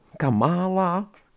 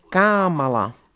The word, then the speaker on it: Kamala: Ať už dáte přízvuk v jejím jméně kamkoliv, jednou to sama vyslovuje tak a jednou onak, takže Kamala nebo Kamála rusky, když to vyslovují. Když začala svou předvolební kampaň, rozhodla se, že nejsprávnější bude přece jen Kamala. A začala se čertit, když jí říkali Kamála.